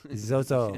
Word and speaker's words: XOXO: His name, Zozo. – Zozo. Yeah. And that's spelled XOXO, like I love you, I love you.